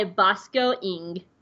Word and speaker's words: Bosco Ng: And it was directed by Bosco Ng.